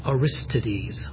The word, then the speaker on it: Aristides: It's A-R-I-S-T-I-D-E-S, Aristides.